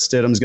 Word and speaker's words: Stidham: And Jarrett Stidham's going to get more involved in the red zone game, I believe.